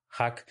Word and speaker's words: Haq: Hay otro artículo muy interesante sobre la RDP, es el artículo de Hack y colaboradores, se escribe HAQ, Hack, eh, es un artículo del 2019 y podemos encontrar ahí una, una mayor profundización clínica sobre la RDP.